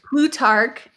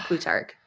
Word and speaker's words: Plutarch: Plutarch. It's like a Tark, like a K, not even though it's spelled with a C. – Plutarch? – Yeah, Plutarch.